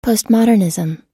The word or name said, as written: postmodernism